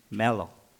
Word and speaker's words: Melo: My real name is Romelo, but people call just call me Melo, okay? Just like Melo Yellow.